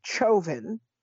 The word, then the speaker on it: Chauvin: His name is Chauvin. At least this is the way they're pronouncing it. It's a French name. It should be pronounced Chauvin, but okay, Chauvin.